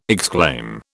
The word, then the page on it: exclaim: British English